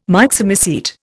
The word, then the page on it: Myxomycete: Indian